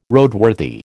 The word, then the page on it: Roadworthy: American English